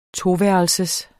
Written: toværelsers

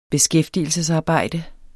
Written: beskæftigelsesarbejde

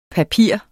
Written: papir

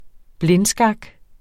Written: blindskak

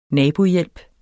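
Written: naboforhold

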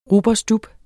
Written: rub

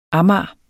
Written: amager

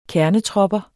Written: KERNETROPPERS

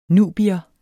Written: nubier

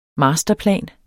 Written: masterplan

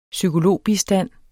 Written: psykologbistand

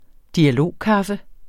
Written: dialogkaffe

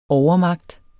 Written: overmagt

